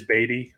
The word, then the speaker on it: Beaty: And like, just to clear this up, it is Beaty. I know, okay. It looks like Beaty if you're looking at it, but yeah, Beaty.